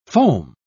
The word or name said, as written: foam